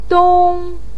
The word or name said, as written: dong1